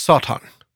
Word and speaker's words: Satan: Huh what I discovered, and it's actually in Hebrew, it's pronounced Satan.